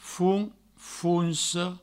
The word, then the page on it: fons, fonsa: founs, founso; Provençal